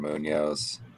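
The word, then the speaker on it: Muñoz: At least pronounce it right if you're going to take my guy. Sebastian Munoz. – I just can't do the rolling in.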